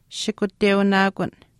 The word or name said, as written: shikuteunaakun